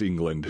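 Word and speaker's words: Englund: That's England with a U. – U. Right? It's right. It's England with a U, but it's still pronounced England.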